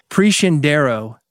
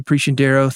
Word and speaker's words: Preciandero: It's Preciandero is how you say Gabby's last name. And here it is spelled out for you so you can find her. Preciandaro. Gabby Preciandero, thank you for being here on Music Business Radio.